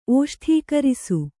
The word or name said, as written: ಓಷ್ಠೀಕರಿಸು